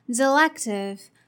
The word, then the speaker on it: selective: Okay, so let's separate the word into three parts: S E, L E C, T I V E. So in the first part, the pronunciation of E here is uh, uh. So the first part is st, st. Now the second part, L-E-C. The pronunciation of E here is E. E. So the second part is leg.